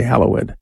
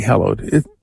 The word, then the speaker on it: hallowed: We say hallowed. The usual pronunciation would be hallowed. We use an older pronunciation, the Our Father, because, well, that's how language happens.